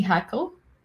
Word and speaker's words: Hackle: Kathy Hackle. – H-A-C. – Kathy with a C? – Yeah, yeah. – H-A-C-K-L-E, I think.